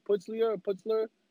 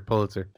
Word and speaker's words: Pulitzer: Putzler? Award winner? Pulitzer.